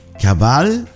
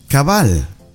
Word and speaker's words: cabal: Cabal in Salvadorian Spanish means exactly, true. And it's pronounced as cabal. Exactly.